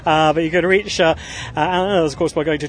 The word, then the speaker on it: Mearns: Mearns. Mearns rhymes with Burns.